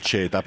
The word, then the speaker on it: CETA: Diciamo che si potrebbe pronunciare CETA, ma noi italiani lo pronunciamo CETA.